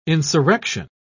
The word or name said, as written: insurrection